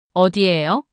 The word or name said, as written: 어디예요?